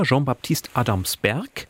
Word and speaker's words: Jean-Baptiste Adamsberg: Also ganz, ganz spannende Figur, Jean-Baptiste Adamsberg. Extra französisch ausgesprochen, man könnte auch sagen Johannes Adamsberg.